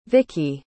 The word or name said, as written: vickey